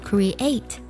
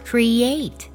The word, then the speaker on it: create: 我们在读的时候需要特别注意啊，字母 E A 之间呢有一个半元音耶的加音，不要读作 create，而读作 create，可以听出来吗？